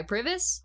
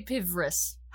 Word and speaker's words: PVRIS: But more importantly, what you just listened to was a wonderful arrangement of Crystallized by the XX, then Death of Me by Privis. I meant to say Pivris how do you say that? P V R I S.